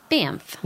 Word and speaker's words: Banff: Banff. – Well, there's the N's. – Yeah. – I mean, can you pronounce it Banff with M's? – It's not. No, it's N-F-F.